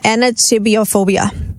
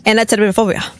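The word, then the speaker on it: anatidaephobia: Anatibiophobia. – Except there's no B anywhere. – I just made it up. – I like that better. – There's no B anywhere in that word. A-N-A-T-I-D-A-E. – Anatidaphobia. Oh. – No, that's exactly how you say it.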